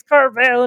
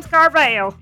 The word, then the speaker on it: Carville: James Carville. – I like how you pronounce his name like the ice cream cake.